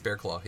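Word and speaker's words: Barraclough: It's actually pronounced Bearclaw.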